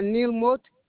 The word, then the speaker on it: Mote: Last name is M-O-T-E. – I'm sorry, your phone is breaking up. Can you just repeat that? – Neil Mott.